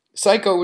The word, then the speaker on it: Sycco: Psycho, which is spelled S-Y-C-C-O, is an 18-year-old pop singer-songwriter from Brisbane, Australia.